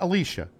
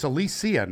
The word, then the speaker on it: Alicia: Well, Alicia, not Alicia, right? It's Alicia now, Silverstone. Yeah. According to her, she came out after 40 years of us pronouncing her name wrong.